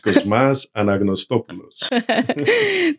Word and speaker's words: Cosmas Anagnostopoulos: It's Cosmas Anagnostopoulos.